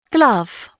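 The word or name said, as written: glove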